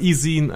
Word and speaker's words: e-zine: So you just said it correctly. E-zine. – E-zine. – It's because of zine. A zine.